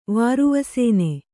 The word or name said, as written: ವಾರುವಸೇನೆ